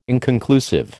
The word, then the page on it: inconclusive: American English